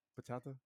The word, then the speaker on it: bachata: Did I say it wrong? Bachata? – No, you got it.